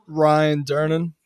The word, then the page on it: Ryan Durnin: male